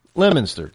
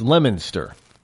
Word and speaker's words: Leominster: Well, it's not called Leominster, but it's spelled that way. But it's pronounced Leominster, just so you know. If you're ever in that neck of the woods of Massachusetts, you know, around Route 2 area, it's Leominster.